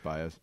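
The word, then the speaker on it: Báez: Javier Baez, or Baez, I believe it's Baez.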